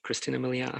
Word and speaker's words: Christina Milian: Christina Milian. Am I saying that right? Christina Milian.